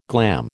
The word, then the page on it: glam: American English